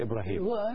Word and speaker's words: إبراهيم: النطق في جميع الحالات إبراهيم.